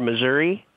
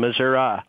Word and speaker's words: Missouri: The first one is be prepared to hear Missouri and Missouri, both of which are acceptable pronunciations of our state's name.